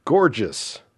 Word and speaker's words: Gorges: It's spelled G-O-R-G-E-S, and I probably would have just said Gorgeous, but he wrote me back and said, no, that's gorgeous. Don't laugh. It's gorgeous.